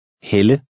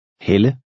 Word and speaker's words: Helle: The first word makes a long E sound, and the second, a woman's name, makes the Danish short E sound. Listen and repeat number 10. – Hel. Helle. Hel. Helle.